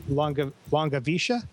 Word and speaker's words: Langewiesche: It's called Aloft, Thoughts on the Experience of Flight, by William, and the last name is, I pronounce, you guys may have said this more often than I have, Longavisha. The same, and I think that's the German pronunciation.